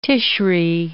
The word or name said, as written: Tishri